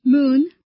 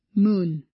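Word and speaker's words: moon (문): The examples in Korean here are bap, which means rice, and moon, which means door. So those are syllables and complete words too. 1. Consonant plus vertical vowel plus final consonant, bop. 2. Consonant plus horizontal vowel plus final consonant, moon.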